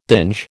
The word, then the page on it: dinge: American English